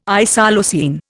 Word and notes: isoleucine: Indian

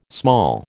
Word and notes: small: American English

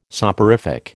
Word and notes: soporific: American English

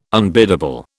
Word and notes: unbiddable: British English

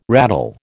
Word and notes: rattle: American English

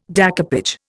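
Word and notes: découpage: Indian

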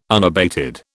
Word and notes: unabated: British English

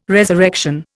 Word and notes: resurrection: Indian

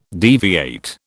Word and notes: deviate: British English

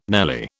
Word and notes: nelly: British English

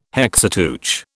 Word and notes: Hexateuch: British English

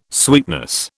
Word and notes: sweetness: British English

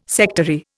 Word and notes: sectary: Indian

